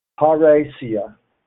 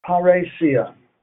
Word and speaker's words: Parrhesia: And he led me to this particular Greek word, Parrhesia. Parrhesia. Freedom to speak the truth boldly. Praise Jesus. I praise Jesus for giving us this particular Greek word. Praise God. It is spelled P-A-R-R-H-E-S-I-A. P-A-R-R-H-E-S-I-A. Parrhesia.